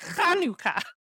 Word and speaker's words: Hanukkah: And you don't even have to spell it with a CH. Just pronounce it with a CH. – Mm-hmm. – Hanukkah.